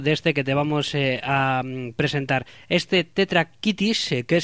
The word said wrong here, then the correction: Tetractis: es, vamos a, lo voy a pronunciar mejor, Tetractis, mejor dicho, vamos a ver, joder, como tengo y la lengua.